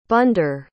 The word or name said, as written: bandar